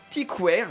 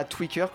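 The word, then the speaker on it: Tykwer: Je me trompe toujours, Tiquer et pas Tweaker comme je le prononce tout le temps.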